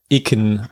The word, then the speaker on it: EKN: Ich glaube, das waren Öko-Sneakers von EKN oder Eken ausgesprochen.